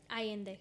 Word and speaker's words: Allende: So Allen apparently felt, or Allende, how do you say it? – Allende.